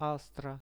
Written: astra

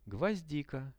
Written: gvozdika

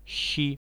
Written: shi

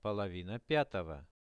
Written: polovina 5-ogo

